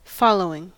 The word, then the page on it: following: American English